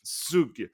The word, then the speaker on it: Zug: Jag vet inte riktigt vad man uttalar Zug. – Zug.